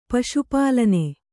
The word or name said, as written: ಪಶುಪಾಲನ